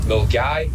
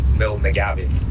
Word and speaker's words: Milngavie: There is one north of Glasgow called Milgai, but it's actually called Milnagabi. But everyone in Milgai gets annoyed if you call it Milnagabi. – What? – Yeah, so it's Milgai is the place, but it's spelled Milngavi.